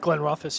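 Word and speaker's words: Glenrothes: I had a bottle of Glenroth's? Is that how you say it? – Glenroth's, yeah.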